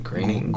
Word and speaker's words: Groening: My problem is that Matt, you know, how do you say his last name? – Groening, yeah.